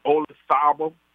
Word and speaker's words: Olazabal: And I tell you what, you remember the golfer from Spain named Olazabal? – Yeah, with the Z in it, but they don't pronounce the Z.